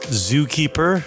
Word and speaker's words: Zookëper: And to be clear, it is pronounced Zookëper. – Yeah. – Okay, I didn't know if there was a special pronunciation with the two dots over the E or not. So, it's just dumb.